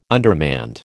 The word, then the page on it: undermanned: American English